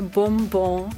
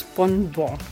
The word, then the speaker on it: Bonbon: Also hier kommt dazu, dass man lieber Bonbon sagt als Bonbon. Das ist ja sehr schwierig. – Ja. – Und so ist tatsächlich diese Aussprache mit dem M in der Mitte auch im Duden zu finden. – Also es gibt quasi B-O-M, B-O-N, Bonbon.